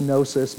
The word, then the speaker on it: gnosis: It comes from the Greek word gnosis. We don't pronounce the G, though. It's just gnosis, but it helps you to visualize it, gnosis.